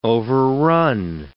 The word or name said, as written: overrun